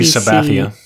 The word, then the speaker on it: Sabathia: Sabathia.